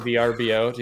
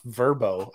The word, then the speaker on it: Vrbo: It's pronounced Verbo. I still say VRBO, but they literally went through the effort of putting out a commercial on national television of like, stop saying VRBO, it's Verbo.